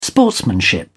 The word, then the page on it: sportsmanship: British English